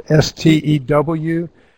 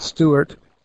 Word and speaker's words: Stewart: It is, and my last name is spelled Stewart. So it's j.stewart, Stewart, at UNC for University of North Carolina, .edu.